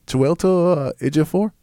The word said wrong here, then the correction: Chiwetel Ejiofor: Chiwetel Ejiofor? – Yes.